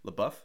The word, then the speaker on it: LaBeouf: I keep saying his name wrong, I'm sure. But LaBeouf? – Yeah.